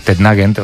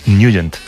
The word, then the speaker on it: Nugent: Hombre, siempre lo hemos llamado en el barrio Tednagent o Tednagent. – Claro, pero yo era de Tednagent. – Pero la pronunciación correcta es Nugent.